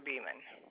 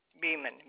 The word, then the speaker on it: Beeman: Is Donna Beeman. – Could you spell that for me? – D-O-N-N-A Beeman, B-E-E-M-A-N.